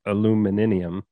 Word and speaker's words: aluminium: I think he's British, but he pronounces it aluminium.